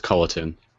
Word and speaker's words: Culliton: There's just no G, so it's Culliton.